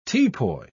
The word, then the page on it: teapoy: British English